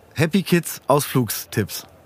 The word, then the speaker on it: HappyKids AusflugsTipps: Und zwar ist hier von Happy Kids Ausflugstipps. Habe ich das richtig gesagt? Das hast du richtig gesagt.